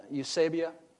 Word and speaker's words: eusebeia: E-U-S-E-B-E-I-A. Eusebia.